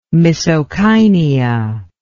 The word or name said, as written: misocainea